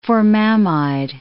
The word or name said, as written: formamide